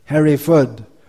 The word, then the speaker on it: Hereford: It's not pronounced ford, it's pronounced Fud. Bradford, Hereford, Watford, Brentford.